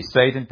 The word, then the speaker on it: Satan: Satan, or I think more correctly pronounced in Hebrew, Satan, almost like an S-A-W. The adversary, the one who resists, is sort of what the word means. We see Satan presented in the first two chapters.